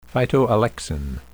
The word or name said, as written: phytoalexin